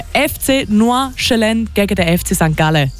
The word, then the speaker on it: Nordjylland: Nordjylland. – that's a really good example. Nordjylland. And perfect. The D at the end is pronounced as well. – The D isn't pronounced. No, it's silent.